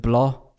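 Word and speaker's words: Blough: Jetzt aber ohne Jared Goff, denn der wurde positiv getestet auf Corona und entweder mit David Blau oder wie wird er ausgesprochen? David Blah. – Blau, Blau glaube ich tatsächlich. Ja. Blau. Ja.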